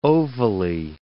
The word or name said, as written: ovally